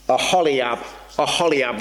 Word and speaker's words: Oholiab: Maybe the other note is to say, Oholiab, that's what I'm going for the pronunciation of this name.